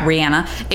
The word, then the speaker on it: Rihanna: There's like 7 different people involved in the song, but for all intents and purposes, it was a Rihanna. That's apparently how you pronounce it. – Yeah. – Rihanna.